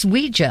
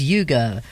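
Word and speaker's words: Uyghur: Yes, Ouija. I'm glad you told me about the pronunciation of this because I mentally I have it as Yuga rather than Ouija. – Anyway, it's a sort of Chinese Y-U-Y-G-H-U-R group in China.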